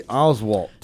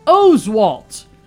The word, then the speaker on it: Oswalt: Happy 45th birthday, Roy Oswalt. – And this is where we remind people that the three time All Star is Roy Oswalt. Different pronunciation for you there.